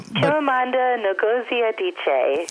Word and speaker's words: Chimamanda Ngozi Adichie: Yes, Chimamanda Ngozi Adichie.